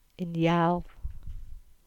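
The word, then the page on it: Gurnard's Head: Cornish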